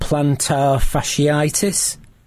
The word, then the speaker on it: plantar fasciitis: Because one of the ones that we had in our top 10 was a thing called plantar fasciitis.